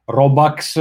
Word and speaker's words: Robux: Bene, ci correggono dalla chat, Giovanni G ci dice che in realtà si pronuncia Robux eh, dal termine Bucks, che eh, è il termine sl- slang per, che significa dollari fondamentalmente.